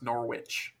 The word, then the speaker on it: Norwich: Well, in the Norwich in Ontario is pronounced Norwich.